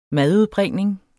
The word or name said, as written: madudbringning